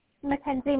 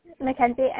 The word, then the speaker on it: Mackenzie: Mackenzie May Smith. Mackenzie, M-A-C, capital K-E-N-Z-I-E. – Okay, and then May as in spelled like M-A-E.